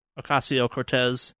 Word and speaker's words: Ocasio-Cortez: Ocasio-Cortez.